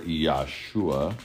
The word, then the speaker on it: Yahshua: Now it came to pass when Joshua, or Yahshua, believe it or not, that is how you say his name in the Hebrew, near Jericho, when Joshua came near Jericho, that he lifted up his eyes and he looked.